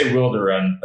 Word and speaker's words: Wilderun: Obviously, a lot of our fans, a lot of people say Wilderun or Wilderun, and that's fine. But we say Wilderun. That's how we like to pronounce it.